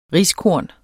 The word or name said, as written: riskorn